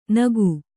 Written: ನಾಕು

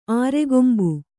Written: ಆರೆಗೊಂಬು

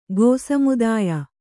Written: ಗೋಸಮುದಾಯ

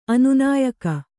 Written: ಅನುನಾಯಕ

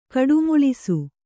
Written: ಕಡುಮುಳಿಸು

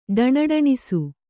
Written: ಢಣಢಣಿಸು

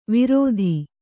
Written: ವಿರೋಧಿ

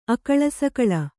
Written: ಅಕಳಾಸಕಳಾ

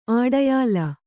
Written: ಅಡಯಾಲ

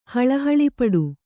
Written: ಹಳಹಳಿಪಡು

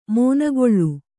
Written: ಮೊನೆಗೊಳ್ಳು

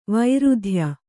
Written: ವೃಥಾಯ

